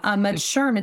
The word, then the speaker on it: SHRM: Yeah, most people think it's like mushroom. But SHRM, it's Society for Human Resource Management.